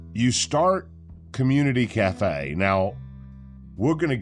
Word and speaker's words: ComeUnity: and it's spelled C-O-M-E-U-N-I-T-Y, Community Cafe.